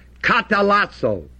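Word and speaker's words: katalasso (καταλλάσσω): Another word for reconcile is the word katalasso, K-A-T-A-L-A-S-S-W. And the grave accent is on the A following the L.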